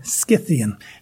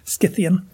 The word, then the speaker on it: Scythian: That C in that word is a kappa or a K in Greek, so it should be pronounced with a hard K, Scythian. But you can say it Scythian. I've said it Scythian too.